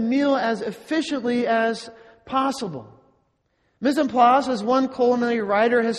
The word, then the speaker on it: mise en place: Pronounced mise en place.